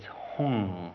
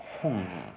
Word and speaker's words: Hum: And the Hum has got to be pronounced with the nose closed.